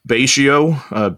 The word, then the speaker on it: Betio: And how do you say it? Betio? – Betio, B-E-T-I-O.